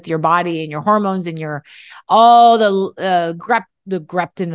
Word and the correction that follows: ghrelin: and the leptin. No, I said it wrong, Christy. – The leptin and ghrelin. Yeah. – Leptin and ghrelin.